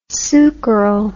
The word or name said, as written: sucaryl